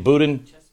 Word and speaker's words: Boudin: They've refused to bat an eye when the son of two Weatherground uh, Underground uh, members, uh, uh, uh, how do you say his last name? Boudin? Yeah. Chesa Boudin, who runs the district, who runs for district attorney in San Francisco, that is that is the son.